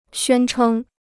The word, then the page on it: 宣称: xuān chēng